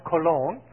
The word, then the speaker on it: Colón: It's pronounced Cologne, like, you know, what we Gents sometimes put on.